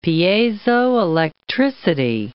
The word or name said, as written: piezoelectricity